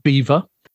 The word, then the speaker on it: Belvoir: It was created by the Duchess of, it's spelt Belvoir, but it was pronounced Beaver.